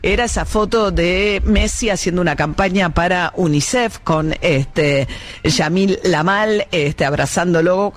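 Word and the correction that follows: Lamine Yamal: Lo dije bien, esta vez siempre lo digo al revés, Lamin Yamal, siempre le doy vuelta el nombre con el apellido,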